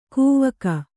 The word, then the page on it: ಕವಿಕೆ: kannada